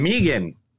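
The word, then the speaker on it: M3GAN: Megan? How do you pronounce it? – It's Megan, but I'm calling it Mithrigan because that's what it says.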